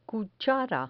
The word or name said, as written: cuchara